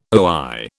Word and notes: oi: British English

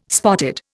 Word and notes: spotted: Indian

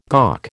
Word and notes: gawk: American English

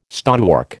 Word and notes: stonework: American English

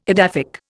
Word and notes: edaphic: Indian

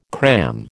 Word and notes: cram: American English

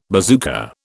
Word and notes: bazooka: British English

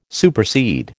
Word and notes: supersede: American English

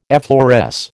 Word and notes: effloresce: American English

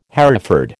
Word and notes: Hereford: American English

